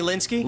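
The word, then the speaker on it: Lynskey: How do you pronounce it? – Melanie Linsky. – Linsky.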